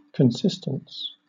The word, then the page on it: consistence: British English